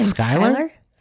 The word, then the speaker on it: Schuyler: I actually don't know how to say his last name. – Schuyler? – It's S-C-H-U-Y-L-E-R. – Yeah, Schuyler, like from Hamilton.